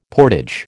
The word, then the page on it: portage: American English